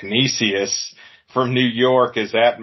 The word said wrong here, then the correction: Canisius: It's, I think it's just Canisius. – Canisius? Canisius? Okay. yeah, yeah. Um, Chris Bevins, our our um, sports editor, kind of um, pronounced it for me when we were talking about it. It's Canisius.